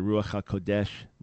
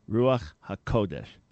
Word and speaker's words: Ruach HaKodesh: But they mispronounce it. They'll say the Ruach HaKodesh. It's Kodesh. Everybody say it with me. Ruach. All right. I know it's a little hard there. Ruach HaKodesh. Ruach HaKodesh. Put the accent on the ko.